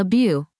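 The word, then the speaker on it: beuh: Weed, in general, is called le bu, pronounced like bud, but with no D, Argentina.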